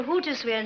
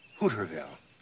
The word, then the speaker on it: Hooterville: All right, darling, you can drive me to Hootersville now. – Sweetheart, as long as we're going to live here, you should learn to pronounce the name of the town correctly, Hooterville.